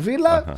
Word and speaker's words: Villa: Α, νόμισα, επειδή βλέπω το Μεντιλίμπαρ, νόμισα ότι είναι τέτοιο, Ιβηρικό. Και λέω τα δύο λάμδα προφέρονται γάμα. – Γι' αυτό επιμένω. – Λοιπόν, καλά κάνεις. – Φράιμπουργκ, Άστον Βίλα, στις 10 σήμερα.